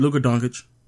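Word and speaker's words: Luka Dončić: You said it right, Luka Doncic.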